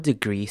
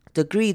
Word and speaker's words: degree: degree Celsius. Degree 这个字呢也是比较特别的，它这个 E 可以，你 either 可以前面的 E 你可以念成长母音 E， degree，或者是呢你也可以念成轻音 degree. What by a kilogram of water by 1 degree Celsius 或是 by 1 degree Celsius，两种念法都是可以的。